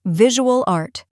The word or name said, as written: visual art